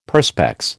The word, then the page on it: Perspex: American English